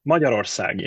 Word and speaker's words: Magyarország: For example, it's similar to me trying to get everyone to pronounce Hungary's name as Magyarország. – So that will never fly. I'm saying Hungary myself. – Yeah, good. Okay. – What is it? Magyarország? – Magyarország, yeah, yeah, yeah.